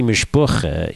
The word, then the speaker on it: mishpacha: I mean, in all honesty, I still can't quite get mishpacha right. – Mishpacha. Well, let's work on that. And it can be mishpucha, you know, because Yiddish has its own variations.